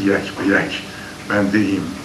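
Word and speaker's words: یک به یک بنده‌ایم: که ما مرتو را یک به یک بنده ایم، تلفظ درست یک به یک بنده ایم.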